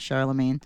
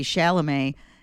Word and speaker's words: Chalamet: Timothy Chalamet? Not Charlemagne. Timothy Chalamet.